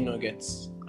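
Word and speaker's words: Knuggets: He is the founder and CEO of K Nuggets. Am I pronouncing that correctly? – Hey, yeah. It's pronounced Nugget. It's like a silent K, like in knowledge.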